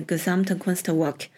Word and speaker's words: Gesamtkunstwerk: 这个词在德语里面发音是 Gesamtkunstwerk，在英语里面直译为 The Total Artwork。